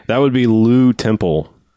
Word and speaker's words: Lew: I like that it's spelled L-E-W. – Also. Oh, of course, yeah. – Lou.